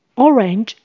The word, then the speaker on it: orange: 请留意 orange 的发音只有两个音节，orange，orange。黑色 a 是发 a 的蝴蝶音，black，black。 Orange means fall harvest and black means death。